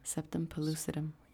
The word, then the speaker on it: septum pellucidum: Septum pellucidum.